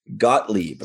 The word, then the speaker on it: Gottlieb: So Q-Bert was developed by a Chicago-based company called Gottlieb. I think that's how you pronounce it. – Correct.